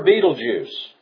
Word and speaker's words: Betelgeuse: The star Betelgeuse. Yes, that's how it's pronounced.